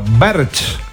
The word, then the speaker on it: Berge: Berge se escribe, ¿no? Berch.